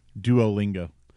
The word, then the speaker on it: Duolingo: Duolingo.